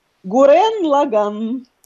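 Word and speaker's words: Гуррен Лаганн (グレンラガン): Гуррен Лаганн. – Гуррен Лаганн. Гуррен Лаганн.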